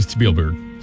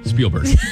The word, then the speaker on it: Spielberg: Steven Spielberg. Spielberg. Sorry, I knew it. I just mispronounced it.